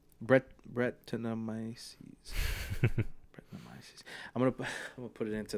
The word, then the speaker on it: Brettanomyces: Brettanomyces.